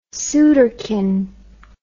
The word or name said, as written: sooterkin